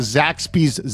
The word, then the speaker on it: Zaxby's: Several weeks ago, Michael said he was interested in trying a Zaxby's salad. That's how they say it, with a Z.